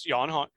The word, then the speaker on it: Jan: We talked about how to pronounce this guy's name. Jan Hammer. – Jan. Yeah, it's Jan. I'm pretty sure.